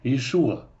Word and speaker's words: Иешуа: Эта же сложность произнесения гортанного звука связана и с произношением имени Иешуа. Это именно вот это последнее «а», оно и не совсем «а» на самом деле.